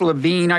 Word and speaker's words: Lavigne: His name is pronounced Levine, I take it, L-A-V-I-G-N-E.